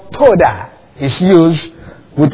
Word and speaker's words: todah: There's a Hebrew word, toda. Well, we can spell it in English as T-O-D-A-H, toda.